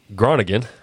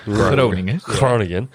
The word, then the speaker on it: Groningen: And I called it Groningen because I wasn't sure how to say it. – Groningen.